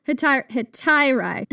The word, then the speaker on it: hetairai: So this is the story of the ancient Greek courtesan, or Hetairai, Phryne. Did I pronounce that right? Hetairai? – Well, that's how I pronounce it, so let's go with it. – Hetairai.